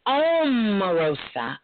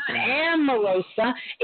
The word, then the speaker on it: Omarosa: It's Omarosa. I'm saying her name right. It's not Amarosa. It's Omarosa.